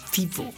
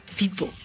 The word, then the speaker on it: vivo: Y se le llama vivo. – Vivo. – Así, su pronunciación es muy vivo.